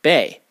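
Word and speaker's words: bei (贝): The early Chinese character for money, pronounced in Mandarin as bei, is in fact a stylized rendering of a cowrie shell.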